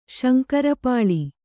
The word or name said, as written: ಶಂಕರಪಾಳಿ